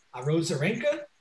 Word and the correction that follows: Arozarena: The stud? – Arozarena? – Arozarena. There he is.